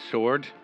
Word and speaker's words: sword: Sword, sword, sword. Why do we not pronounce the W?